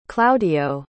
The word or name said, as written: claudio